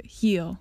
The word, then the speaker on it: heal: の意味は heal が「傷が癒える治るという意味で The last time が前回という意味です傷が癒えるという意味の HEAL とスペルする Heal とかかとを意味する HEEL とスペルする Heal はスペルは違うんですが発音は同じになります